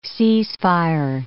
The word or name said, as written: cease-fire